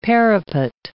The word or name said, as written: parapet